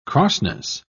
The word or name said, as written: crossness